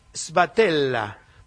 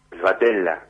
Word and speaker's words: Sbatella: Muy buenos días, José Sbatella, vamos a pronunciarlo en italiano, porque muchos le dicen Sbatella, no, Sbatella, presidente de la Unión de Información Financiera.